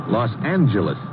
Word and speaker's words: Los Angeles: Correction. Los Angeles. – Oh? – Yeah, soft G.